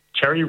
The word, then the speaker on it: Chery: Well, next we're looking at the new, is it pronounced Chery? Chery, yeah.